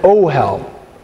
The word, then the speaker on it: ohel: In Hebrew, a tent is an ohel. O-H-E-L. Everybody say ohel. – Ohel. – Yes. Emphasize the last syllable in that word. Alright. Don't emphasize it.